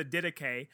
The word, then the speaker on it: Didache: If it's any consolation to you, so you just made reference to the Didache, and you pronounced it correctly, and I've grown to appreciate that.